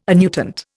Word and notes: annuitant: Indian

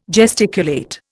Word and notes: gesticulate: Indian

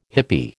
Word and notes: hippie: American English

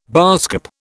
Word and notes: baasskap: British English

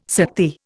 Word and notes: Sakti: Indian

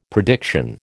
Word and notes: prediction: American English

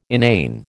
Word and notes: inane: American English

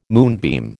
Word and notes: moonbeam: American English

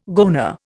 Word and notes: goanna: Indian

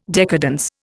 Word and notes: decadence: Indian

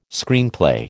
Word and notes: Screenplay: American English

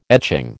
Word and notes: etching: American English

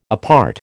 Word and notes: apart: American English

